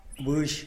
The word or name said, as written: bux